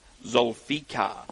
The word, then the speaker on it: Zulfikar: I actually got a SMS yesterday of how to pronounce that horse, and I know I just butchered it. So it is Zulfikar. That's how we're told to pronounce it.